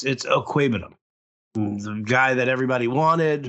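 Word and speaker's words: Okwuegbunam: It's Okwebenum. It's not a difficult one.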